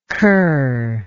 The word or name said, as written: curlike